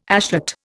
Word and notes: ashet: Indian

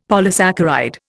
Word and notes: polysaccharide: Indian

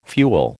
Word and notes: fuel: American English

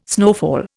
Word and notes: snowfall: Indian

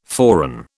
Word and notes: forerun: British English

